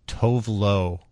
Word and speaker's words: Tove Lo: Tone Loke? – Tove Lo. Tone Loke? No, Tove Lo. Or I've heard her name is actually, because she's Swedish, it's pronounced Tove Lo, but I say Tove Lo.